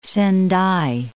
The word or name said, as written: Sendai